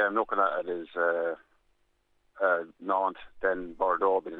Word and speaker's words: Nantes: N-A-N-T-E-S, Nantes is the way they pronounce it.